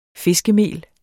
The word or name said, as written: fiskemel